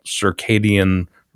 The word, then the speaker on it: circadian: So one of the things that is one of the things that I had heard some time ago was this thing about circadian rhythm.